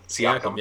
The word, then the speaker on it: Siakam: Siakam, yeah.